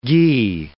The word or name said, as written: gie